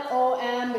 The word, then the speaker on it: om: But so we spell it OM because it sounds like om, right?